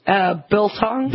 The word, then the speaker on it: biltong: Biltong.